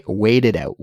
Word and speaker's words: wait it out: It's not a harsh T sound. It's more of a softer, almost between a T and a D sound, but that's how we pronounce it, to wait it out.